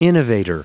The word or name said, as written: innovator